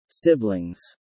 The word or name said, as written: Siblings